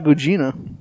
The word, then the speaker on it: Gugino: But, Oh, Carla Gugino.